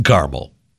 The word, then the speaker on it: caramel: And how many syllables in caramel?